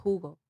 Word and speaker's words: jugo: So the J in Spanish has that like ha. Ha, yeah, like it's H. – Yeah. – like juice in Spanish is spelled J-U-G-O and it's pronounced Hugo.